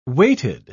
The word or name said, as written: weighted